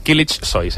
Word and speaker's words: Kılıçsoy: Kılıçsoy, sí. És una mica difícil de dir, perquè hi ha la C trencada pronunciada en turc i després la S, però sí, així és.